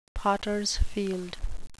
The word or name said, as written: potters field